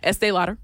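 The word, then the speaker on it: Estée Lauder: Yeah, Estee Lauder.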